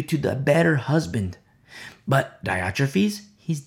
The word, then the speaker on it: Diotrephes: He says, I wrote to the church, but Diotrephes, I've heard it pronounced either way. We'll say Diotrephes.